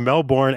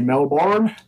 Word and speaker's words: Melbourne: I always love the way Americans say Melbourne. Yeah. What's we just say Melbourne. – Melbourne? I can't say it that way. Melbourne. – Okay. It's like Melbourne, you know?